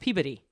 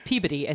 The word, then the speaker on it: Peabody: It's actually pronounced Peabody. It's spelled Peabody, like Mr. Peabody, but it's Peabody, and he lost.